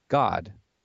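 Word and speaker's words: Gad: which is pronounced God in the Hebrew language.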